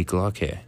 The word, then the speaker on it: Glocke: Or, in German, it would be known as Die Glocke. And it's spelled Die Glock, but Glock has an E at the end.